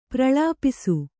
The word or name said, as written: ಪ್ರಲಪಿಸು